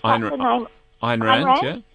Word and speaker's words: Ayn Rand: Ayn Rand, yeah.